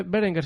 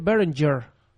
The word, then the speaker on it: Berenger: no se dice Berenger, se dice Berenger.